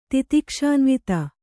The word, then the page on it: ತಿತಿಕ್ಷಾನ್ವಿತ: titikṣānvita